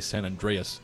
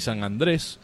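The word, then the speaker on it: San Andrés: I think it's become so widespread in the cigar Spanish speakers have started to say San Andreas. – I know, as if you know, they okay, I had it wrong. Let me say it right. San Andreas. – Crazy. So anyway, yes, if you ever see San Andreas rapper referenced out there, know that it's got an extra A. Should be San Andres, which references the...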